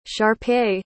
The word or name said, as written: shar-pei